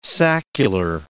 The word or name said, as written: saccular